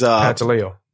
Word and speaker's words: Pantaleo: No, not, but the officer is Pantaleo. – Pantaleon was finally fired. – Pantaleo.